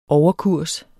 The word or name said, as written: overkurs